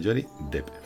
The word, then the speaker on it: Johnny Depp: Sí, se pronuncia Johnny Depp.